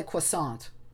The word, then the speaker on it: croissant: I was just going to say I would go the more American way, which is I would pronounce the T. I would say croissant. – But still with the qua sound. – I would do qua, not craw.